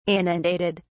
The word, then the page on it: inundated: British English